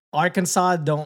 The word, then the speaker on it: Arkansas: Arkansas they spell the same. They still don't pronounce it the same. But, you know, oh, yeah. The sauce, the SAS.